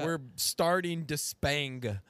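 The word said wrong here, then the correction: Despaigne: I think that's how you say it. Despagny? – It's Despagne. – Despagne. – Despagne is how you pronounce it.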